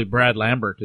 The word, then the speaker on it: Lambert: It's probably Brad Lambert, isn't it? – I believe so, yeah. – I wouldn't go with the French pronunciation, I wouldn't expect. But no, it's the Saskatchewan pronunciation.